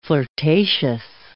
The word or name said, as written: flirtatious